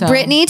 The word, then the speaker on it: Brittainy: At Brittany Taylor. – At Brittany Taylor, yeah. My name is spelled a little weird. – B-R-I-T-T-A-I-N-Y. Mm-hmm. – Looks like Brittany.